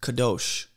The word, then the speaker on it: kadosh: And weirdly enough, in Hebrew, the word holy is pronounced kadosh.